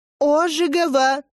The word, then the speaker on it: Ожегова: «Познакомьтесь, Ангелина Ожогова». – «Ожегова», — поправляет из коридора женщина в очках. «Ударение на первый слог, как толковый словарь».